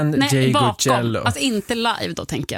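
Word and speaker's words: Ndegeocello: Ngeyoe. Michelle Ngeyoe. – Nej, Ngeyocello. Ngeyocello, ja. Kanske.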